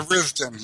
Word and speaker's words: Tharizdun: For instance, the Forgotten Temple of Tharizdun, or Tharizdun, I don't know how you pronounce it in English. – Yeah, Tharizdun. Yeah, you said it right, yep.